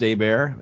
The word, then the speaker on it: Hebert: Again, that last name is H-E-B-E-R-T. So Kyle Hebert, if that helps you remember it. But pronounced Abear.